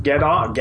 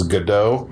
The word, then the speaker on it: Gadot: Which every time I saw it prior to hearing it pronounced correctly, I thought was Godot. – Godot. – Like, I just assumed it was a French pronunciation, but it's not.